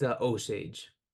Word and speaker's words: Osage: Are we going with Osage? – It's Osage. – Osage. – I believe it's a Osage.